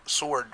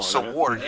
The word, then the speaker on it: sword: I always feel like I say sword funny. – Sword. Because you say it wrong. – Sword, yeah. Well, I always kid around with kids and whatever. Sword. – Silent W, like your Tuck Otts. Silent W's.